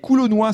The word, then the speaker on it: coulonnois: Étienne Georges, qui est coulonnois, c'est ainsi qu'on prononce, qu'on oui. – appelle les habitants de Coulogne.